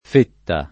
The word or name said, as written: fetta